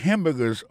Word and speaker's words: hamburger: On a remarqué que dans le mot hamburger, ça a comme d'habitude, il y a trois syllabes là-dedans. Quand on appuie et puis plus ça, c'est le premier syllabe là.